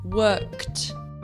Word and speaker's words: worked: Mas preste atenção, este ed tem pronúncia de t. Apesar de você escrever ed, o som é de t. Então vamos iniciar. O primeiro verbo, trabalhar, que seria no passado, worked, worked.